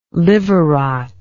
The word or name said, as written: liver rot